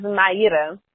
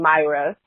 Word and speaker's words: Maíra: My name is Maíra. That's the way that you pronounce it in Brazil. But after a while living in the U.S., it actually became Maíra.